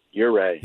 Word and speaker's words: Ouray: Right, Ouray.